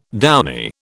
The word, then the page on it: downy: British English